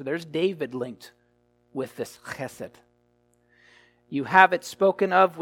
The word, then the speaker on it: chesed: That is the Hebrew word chesed. That's an important word for you to know. Chesed. Well, it's C-H, it's the C-H, hard C-H sound, E-S-E-D.